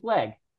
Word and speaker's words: Legge: It is just Leg. Okay. Yeah. Overthinking it. Legge, everybody. – I've already mispronounced it in interviews myself. – I just said that, and people are like, dude's an English teacher and he doesn't know how to pronounce Leg. There's an extra G and an E, everybody.